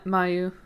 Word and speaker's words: Mayu: Okay, is Mayu, is that how you say it? – Oh yeah, Mayu.